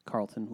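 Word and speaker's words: Karlton: I am at Carlton with a K.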